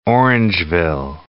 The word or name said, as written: Orangeville